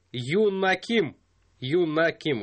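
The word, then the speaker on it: Юн На Ким (김연아): Впереди спортсменка из Южной Кореи Юн На Ким, вот так произносится.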